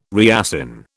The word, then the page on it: reassign: British English